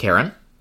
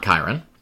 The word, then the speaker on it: Charon: They go up and we meet Charon, who is not Chiron.